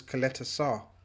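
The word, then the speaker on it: Ćaleta-Car: Our listeners and viewers last night sort of convinced us that it was Coletta Sarr.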